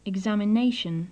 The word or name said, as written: examination